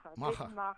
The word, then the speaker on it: MAHA: are maca and quasi maca. Am I pronouncing them right? – Maha.